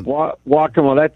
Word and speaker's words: Waccamaw: So Waccamaw, that I think that's right.